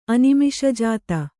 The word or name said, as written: ಅನಿಮಿಷಜಾತ